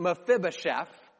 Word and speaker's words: Mephibosheth: His name is actually pronounced Mephibosheth.